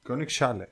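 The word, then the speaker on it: Königsallee: Πρώτη μα στάση το Άλε. Έτσι προφέρεται. Κιόνιξ Άλε. Κιόνικ Σάλε.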